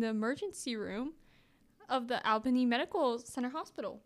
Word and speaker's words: Albany: So, after graduating from high school, he became a pre-med student at the State University of New York's, I'm not sure how to say this, it's like Albany? A-L-B-A-N-Y? Albany? – Yeah. – That sounds right. – Albany, New York.